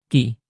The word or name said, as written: q